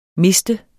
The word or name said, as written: miste